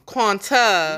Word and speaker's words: Quanta: So, Quantum, they was quiet about the whole thing. I said Quantum. Quanta. – You said it right the first time. – No, it's Quanta.